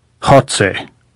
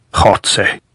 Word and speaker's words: Goltz: How do you say Goltz in Spanish, Comrade General? Hotze, said Goltz, grinning, making the sound deep in his throat as though hawking with a bad cold. Hotze, he croaked.